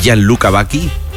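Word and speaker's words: Gianluca Vacchi: Entonces, ¿usted sabe quién es Gianluca Bacchi? ¿Lo he dicho bien, María José? – Sí.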